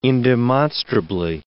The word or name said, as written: indemonstrably